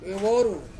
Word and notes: veworu: Ansus

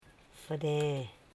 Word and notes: fere: fe.re

